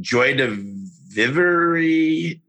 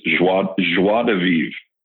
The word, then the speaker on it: joie de vivre: I'm going to butcher this, but Joy de Vivre. Can you go ahead and say that for me? – Yeah, it's a French term. It's joie de vivre.